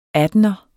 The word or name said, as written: attener